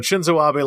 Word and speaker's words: Shinzo Abe: I think I said his name wrong earlier, but Shinzo Abe like approved the deployment of Japanese troops on foreign soil, huge step for Japan militarily.